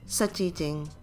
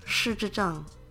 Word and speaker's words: 失智症: In Cantonese, it's 失智症. In Mandarin, it's 失智症.